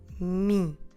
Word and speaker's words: mim: It is similar to the English pronoun me, but this time we need to make it nasal. So first of all, como se escreve em português me? Me. M-I-M. Me. Notice that when we pronounce this word, we need to keep the mouth open at the end. So don't close your lips.